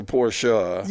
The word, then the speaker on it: Porsche: It's a Porsche. – You drive a Porsche, and you just corrected me on the pronunciation of the name.